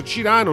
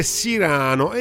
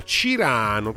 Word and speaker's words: Cirano: e scrivere insomma si scrive Cirano e pronunciare proprio Cirano. Noi chiaramente che ricordiamo il Cyrano di Bergerac tendiamo a dire Cirano, e invece no, Cirano, proprio Cirano così come è scritto,